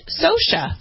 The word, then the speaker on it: Scioscia: It's Mike Scotia.